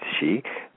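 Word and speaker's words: sídhe: Yes, the Celtic she, pronounced she, the fairy folk, which include fairies, elves, gnomes, leprechauns, and fey animals.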